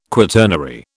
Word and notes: quaternary: British English

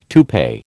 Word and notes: toupee: American English

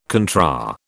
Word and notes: contra: British English

